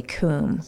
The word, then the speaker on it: Cwm: It goes through the treacherous Khumbu Icefall and Western Qum, which is spelled C-W-M, but you say Qum. – C-W-M.